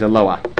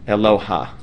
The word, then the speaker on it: Eloah: The word, by the way, is Eloah. Okay, no ha sound. Okay, just for those, nobody does halal here. When I'm around, but just know that the word's pronunciation is Eloah. And if you want to do it wrong, but not totally wrong, you can say Eloah, but it is not ha. It is not magbiha. It is not gavoha. That's a patach genuva. You won't say nocha or rucha, then don't say eloha.